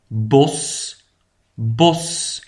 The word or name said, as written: het bos